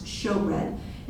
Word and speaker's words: showbread: Then the third step was the table of showbread, and that is the way it's pronounced, showbread.